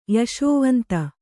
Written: ಯಶೋವಂತ

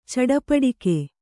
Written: ಚಟಪಟಿಕೆ

